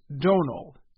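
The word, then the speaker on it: Domhnall: And in October, Donald Gleason jokingly called out television show host Stephen Colbert for saying his first name wrong. The right way to say it is Donal.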